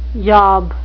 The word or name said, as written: yob